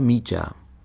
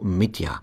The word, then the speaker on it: Míťa: Znělo to jako Mitja. Správná, měkká výslovnost slovansky mazlivé zdrobněliny jména Dimitri je v němčině prakticky nemožná. Käthe tomu chlapíkovi proto od prvého momentu říkala po svém Miča.